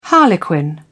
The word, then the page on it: harlequin: British English